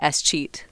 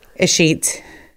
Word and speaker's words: escheat: First thing I needed to ask you is, how do you say escheat? – Escheat.